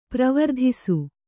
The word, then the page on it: ಪ್ರವರ್ತಿಸು: pravartisi